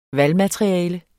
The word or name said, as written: valgmateriale